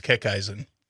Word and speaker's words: Kickeisen: It's pronounced Kickeisen. – Kickeisen. – Yep. – Kickeisen. Rah. – Yeah. Yeah. – Slightly German. – Kickeisen. – Correct.